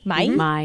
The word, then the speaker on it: Maille: So there's this great mustard place called, I'm going to mispronounce it, Maille? – Uh-huh. – Maille. Okay, so we have a bunch of those.